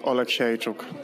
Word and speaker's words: Oleksiejczuk: Michał Lord Oleksiejczuk. – That's close. He says like a chook at the end. – Right. – Michał Lord Oleksiejczuk.